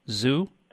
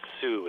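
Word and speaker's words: Xu: How would you pronounce that? Zoo? – Shoe, I assume. Shoe? – That is a former monetary unit of Vietnam equal to 1/100th of a dong. – Yeah. Sue, it seems.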